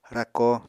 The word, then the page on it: rako: Apatani